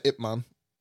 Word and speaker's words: Ip Man: Wait, IP Man? – Yeah. The Ip Man. – Oh. Is that that's how you say it? – Oh. – Yeah, that's how you say it. IP Man.